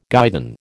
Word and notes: guidon: American English

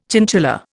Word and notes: chinchilla: Indian

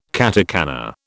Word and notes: katakana: British English

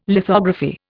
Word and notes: lithography: Indian

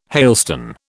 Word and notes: hailstone: British English

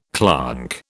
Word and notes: clonk: British English

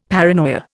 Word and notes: paranoia: Indian

